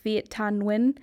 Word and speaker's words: Viet Thanh Nguyen: But there's a Vietnamese-American author named, I believe it's pronounced Viet Thanh Nguyen,